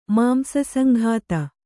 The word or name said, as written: ಮಾಂಸಸಂಘಾತ